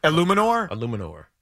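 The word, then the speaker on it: Eluemunor: I can't pronounce his name. Jermaine Illuminor. Illuminor? – Illuminor.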